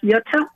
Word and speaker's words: Jutta: My sister's name, it's a German name, it's pronounced Jutta. It's spelled J-U-T-T-A, and Paul, her husband.